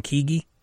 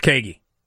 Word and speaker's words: Keaggy: It's K-E-A-G-G-Y, so I'm saying Keaggy. Could be Keaggy. Or Keaggy. Or Keaggy. – I believe that is the phonetic, so I think you're okay. – It's Keaggy.